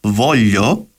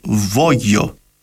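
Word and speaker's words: voglio: το ρήμα θέλω προφέρεται βόλιο στα επίσημα ιταλικά, αλλά σε κάποιες περιοχές προφέρουν το μεγεϊσμό ως βόγιο.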